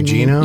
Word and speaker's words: Gugino: As Carla, I always butcher her Gugino? – Name.